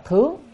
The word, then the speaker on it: thướng: Dâng lên cho Phật chứ phải đọc là thướng thì mới dâng lên được, chứ không phải cầm một cái hoa đâu, mà dâng lên cho Phật nha. Dâng lên thì cho nên đọc chữ thướng chứ không phải đọc thượng được,